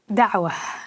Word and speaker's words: Dawa: Dawa is the way it's pronounced.